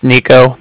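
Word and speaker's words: Niko: Like I said, my name's Nico. That's N-I-K-O.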